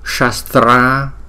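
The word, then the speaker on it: śastra: Rồi khi mà chúng ta thấy chữ s có dấu sắc ở trên chúng ta đọc là sh, cũng giống như chữ sugar của tiếng Anh đó, sugar. Shastra samgara, shastra samgara.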